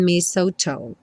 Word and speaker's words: mistletoe: Não se confunda com esse TLE aí. Mistletoe.